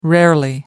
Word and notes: rarely: American English

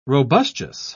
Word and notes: robustious: British English

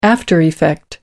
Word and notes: aftereffect: British English

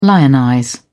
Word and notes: lionize: British English

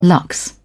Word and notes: lux: British English

